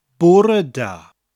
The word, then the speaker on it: bore da: Bore, spelt B-O-R-E, like the English word bore. Oh, I'm so bored. That kind of bore. But with an extra E, an air sound when pronounced. Bore.